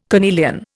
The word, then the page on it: carnelian: Indian